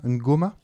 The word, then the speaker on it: ngoma: Alors, est-ce que je prononce bien ? Ngoma ? – Ngoma, oui, c'est okay.